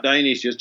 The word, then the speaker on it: Dionysius: Yeah, Dionysius, just like the Greek god, but spelled a little bit differently.